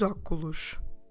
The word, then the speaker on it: óculos: Okay, so for instance, if I want to say I put on my glasses, I say eu ponho os óculos.